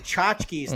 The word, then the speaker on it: tchotchkes: So tchotchkes. I found out they're called tchotchkes. It's spelled with a T. – Like a Polish? – Yeah, it's like T-C-H. There's like two T's in it.